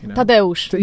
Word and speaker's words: Tadeusz: How would you say Thaddeus in Polish? – Tadeusz.